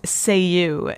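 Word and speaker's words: Céu: And the artist is Seiu. Her name is spelled C-E-U, and this is from her album entitled Seiu.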